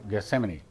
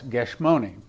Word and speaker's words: Gethsemane: But we've been there, and that evening, Jesus went into the Garden of Gethsemane, or Gethsemane, the way we pronounce it in English, but in Hebrew it's Gethsemane, which means house of bread.